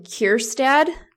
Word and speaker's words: Kjerstad: And I watched Heston Kjerstad. Am I saying that right? – Yep.